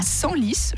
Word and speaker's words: Senlis: Alors, ce Simon, il est né dans l'Oise, à Senlis, je crois que ça se prononce comme ça, oui.